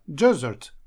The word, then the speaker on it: desert: Потому что у нас есть еще и слово desert, которое означает пустыня. Но оно, пустыня, соответственно, пишется с одной буквой S, и ударение падает на первый слог.